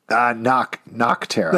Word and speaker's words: Nocterra: Did you say Nutterra? Don't pronounce it weird. – Nocterra. – Thank you.